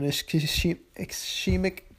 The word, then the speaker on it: ischemic: Moving on, if you go on, you can see that congestive heart failure is actually, it's normally the result of an ischemic disease. I said that wrong, but it's spelled I-S-C-H-E-M-I-C, ischemic disease, which is a lack of oxygen to tissue, to an organ or tissue.